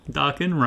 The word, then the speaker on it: Dokken: Dokken rhymes with rockin'.